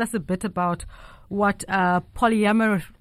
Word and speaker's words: polyamory: Polyamory. – Yes, polyamory.